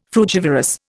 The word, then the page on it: frugivorous: Indian